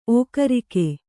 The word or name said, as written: ಓಕರಿಕೆ